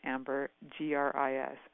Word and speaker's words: ambergris: It's called ambergris, and I'm going to spell it for you, A-M-B-E-R, like amber, G-R-I-S, ambergris.